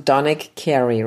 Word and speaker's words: Donick Cary: Do you pronounce it Donick Cary, right? Is that yeah? Perfect.